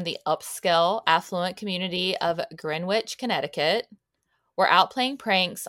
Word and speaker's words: Greenwich: So people pronounce Greenwich, Connecticut as Greenwich.